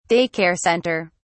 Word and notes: day care center: British English

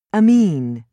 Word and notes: amine: British English

